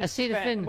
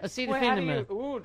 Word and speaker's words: Acetaminophen: Acetaminophen. – What is that word? – Acetaminophen.